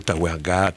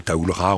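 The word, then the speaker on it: Tawergha: On dira tantôt Tawerga, tantôt Taoulra, on dira comme on peut parce qu'il y a plusieurs prononciations possibles.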